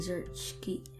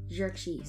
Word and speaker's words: Xerxes: Because when King Xerxes? Ah, I said it wrong. Xerxes.